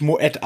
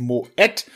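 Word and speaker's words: Moët: Aber ich gönn mir einfach mal hier ein Glas Moet äh, spricht man übrigens wirklich Moed aus, da sind zwei Punkte über dem E. Die meisten sagen immer Moe, aber nein, es ist Moed.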